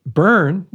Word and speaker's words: Byrn: But Byrn, everybody always B-Y-R-N.